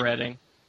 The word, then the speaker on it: Reading: Reading, Pennsylvania, I think is what we settled on the pronunciation of the place, yes. – Right? – Yeah, Reading.